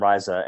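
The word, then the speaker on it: Rhiza: So yeah, you can pronounce it however you want, John, but we pronounce it RZA, and it's just the Latin word for root.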